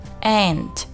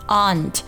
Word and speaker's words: aunt: 再来我们看第二个单词啊，这个单词 aunt，这个单词有两种发音啊，一个就是字母 a 发的是一个梅花音 a 的音 aunt，第二种发音呢是 aunt，就是字母 a 发的是一个 a 的音，这两种音都是可以的。